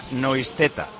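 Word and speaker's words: Neustädter: Pues espérate, que el 33 dice Marcos López, que le tengo aquí, que se pronuncia Noisteta. Escribe Neustadter.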